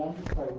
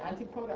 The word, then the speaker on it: antipode: Is it pronounced I never know if it is antipode or antipode. – Oh, I don't know. That's antipodean. When you turn it into an adjective, it becomes antipodal or antipodean. – Because Australia and New Zealand are the antipodes. – I suppose if I was really doing Greek pronunciation, it should be antipode. – So I suspect it's probably antipode.